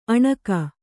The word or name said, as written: ಅಣುಗಿ